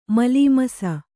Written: ಮಲೀಮಸ

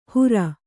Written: ಹುರ

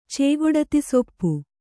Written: ಚೇಗೊಡತಿಸೊಪ್ಪು

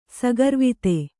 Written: ಸಗರ್ವಿತೆ

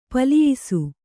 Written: ಪೆಲ್ಲಯಿಸು

